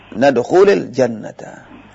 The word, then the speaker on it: nadkhulil jannata: Nah, cara membacanya adalah nadkhulil, bukan berarti lam itu kasrah, tidak. Lam tidak bisa kasrah. Nah. Itu cara membaca. Iya. Nadkhulil jannata.